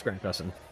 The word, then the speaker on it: Grant Gustin: Yeah, it's Grant Gustin.